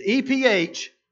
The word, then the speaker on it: Ephphatha: It's Ephphatha.